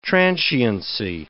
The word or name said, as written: transiency